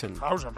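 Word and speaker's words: Huijsen: Bueno, atención, porque el Madrid firmado tiene a Hausen, como dicen ahora. ¿Se dice así, Melchor? – Hausen, perfecto.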